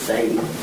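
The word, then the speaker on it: tsadi: The one that starts with a T is Sadie. – TZ. – Wow. – TZADDI. – I'll never remember that because it starts with a T. – It's spelled a couple ways, but that's close enough.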